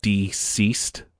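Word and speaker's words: DCeased: Some new Batman collectibles are coming, and then they announced a new event storyline called Deceased. And it's spelled DC and then East, you know, like dead.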